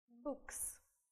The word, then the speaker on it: books: Если у нас окончание «s» стоит после глухой согласной, то будет оно произноситься как «с». Да? То есть в транскрипции будет звук «с». «Books».